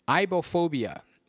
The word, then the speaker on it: aibohphobia: It's tough to pronounce, but it is ibophobia, A-I-B-O-H-P-H-O-B-I-A, ibophobia, from the Latin root ibo, meaning palindrome, ibophobia.